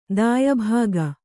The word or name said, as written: ದಾಯಭಾಗ